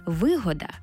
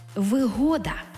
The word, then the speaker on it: вигода: це вигода з упевненим наголосом на другий склад.